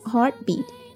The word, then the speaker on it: heartbeat: 心跳声呢，其实还可以说是 heartbeat, heartbeat, 中间的 t 可以不发音， heartbeat, heartbeat.